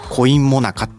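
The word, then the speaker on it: 古印最中: 違うんですよ、お金じゃなくてですね、古い印鑑の印と書いてコインモナカって読むんですね。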